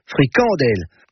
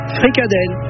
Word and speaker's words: fricadelle: Alors, une AD, ils disent fricadelle, et une AD des autres, ils disent fricandelle. En bon François, en tout cas. La bonne prononciation francophone en France ou en Wallonie, c'est fricadelle. Fricandelle chez les flahutes.